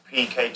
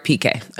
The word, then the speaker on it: Piqué: We are with Gerard Piqué. – Gerard Piqué.